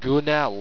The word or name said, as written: gounnel